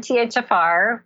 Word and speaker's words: MTHFR: So I got my genetics tested, and I found out that I have this MTFHR gene. How do I say that there's something uh wrong with it? SNP, mutation. Right. So yeah. Got- you've got an MTHFR, which is part of our methylation process, and you've got it's a SNP, SNP, or I describe it as a little glitch.